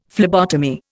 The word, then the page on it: phlebotomy: Indian